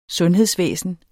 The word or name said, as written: sundhedsvæsen